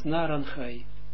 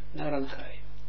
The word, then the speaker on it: Naranchai: In traditie, om dat uit te spreken als Naranchai. Netzach, Ruach, Ne'er, Naranchai. Zo moet je ook uitspreken. Naranchai.